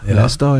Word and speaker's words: Leicester: Leicester, ja.